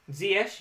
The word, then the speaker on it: Ziyech: I could save 3 million by having Ziyech. Did I say that right? – Ziyech, yes. – By having Ziyech, who's playing Newcastle in the same week that West Brom are playing Manchester United.